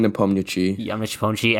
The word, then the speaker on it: Nepomniachtchi: Nepomniachtchi? Is that how you pronounce his last name? – Yeah. Jan Nepomniachtchi.